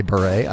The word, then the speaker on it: Bure: You say Bure, I say Bure. – That's how you pronounce it.